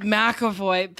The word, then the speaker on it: McAvoy: On Instagram Live, someone asked James McAvoy, is that how you say it? – McAvoy? – Oh, God. God, McAvoy.